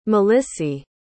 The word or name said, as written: malissie